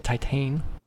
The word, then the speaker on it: Titane: It's spelled the same as Titanic, but because it's French, you say it Titane.